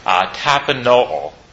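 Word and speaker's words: tapanoo (ταπεινόω): If we look at the word that's used here for a base, it's Strong's 5013, tapanoo, is the way Strong's instructs us to pronounce that word.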